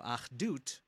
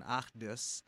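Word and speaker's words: Achdut (אחדות): So Achdut, or in Ashkenazi or Yiddish pronunciation, Achdus, Achdut means oneness, or I don't want to say unity, but oneness.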